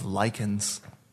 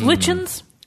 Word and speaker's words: lycan: and then you fight in this order, and there's been a uprising of lycans, werewolves, as they're also called. – Lichens. – Lichens. – Yeah. I'm pretty sure that's how you pronounce it. – I don't think so. Yeah, Underworld says lycan.